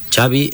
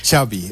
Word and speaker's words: Xavi: Xavi Hernández. – Xavi. – Okay. Yeah, Xavi like chocolate.